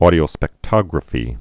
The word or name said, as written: audiospectrography